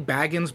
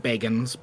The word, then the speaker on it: Bagans: Is it Bagans or Baggins? – So, it is technically Baggins, but he pronounces it Bagans because, he is very particular, but he also likes to mispronounce words.